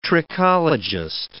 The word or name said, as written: trichologist